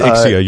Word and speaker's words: Ixia: It is Ixia. You are correct. I am wrong in my pronunciation, as usual.